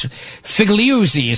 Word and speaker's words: Figliuzzi: Frank Fugazi from MSNBC. I think it's Figliuzzi is how you say it.